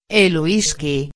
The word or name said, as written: el whisky